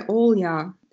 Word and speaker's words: Olya: And I go by Olya. It's O-L-Y-A.